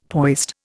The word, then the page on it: poised: Indian